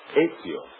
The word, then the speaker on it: Ezio: Ezio. E-Z-I-O. Ezio. Pinza. P as in Peter. I-N-Z-A. Ezio Pinza.